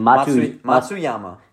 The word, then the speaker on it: Matsuyama: Matsuyama.